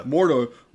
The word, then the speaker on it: Mordo: Mordo.